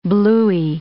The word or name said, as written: blooey